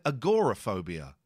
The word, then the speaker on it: agoraphobia: a woman who has, as we don't call it agoraphobia now, we call it agoraphobia. – Oh. – Yeah, you pronounce the O now. We didn't used to.